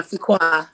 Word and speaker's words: Fuqua: So, and then from Fresno, you went ahead and you moved to San Francisco, where you came in contact with Harvey Fuqua. Is that the correct, is that how we pronounce his name? Fuqua? – Yeah, Fuqua. Harvey, yes. Fuqua.